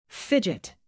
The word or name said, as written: fidget